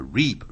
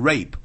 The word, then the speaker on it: reap: You will reap, reap 就是收获，不要念成 rape, rape 是代表强奸 ,R-A-P-E, 是 reap, R-E-A-P, you will reap what you sow, sow 是 S-O-W, 你会收获了你所耕耘的东西 ,sow 就是耕种啊 ,S-O-W, 播种等等。